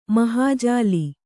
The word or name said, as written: ಮಹಾಜಾಲಿ